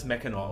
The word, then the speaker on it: Mackinac: Wird aber beides, wird alles Mackinac ausgesprochen, egal wie es geschrieben wird.